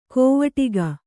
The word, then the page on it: ಕೋವಟಿಗ: kōvaṭiga